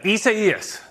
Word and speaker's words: Isaias: Yeah, Isaias.